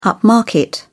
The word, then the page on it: upmarket: British English